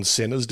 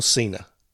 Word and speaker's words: Cena: Anyway, so sorry, Cena or Cena? – Cena. John as in like, Cena. You can't see me.